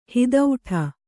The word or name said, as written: ಹಿದೌಠ